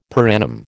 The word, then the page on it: per annum: American English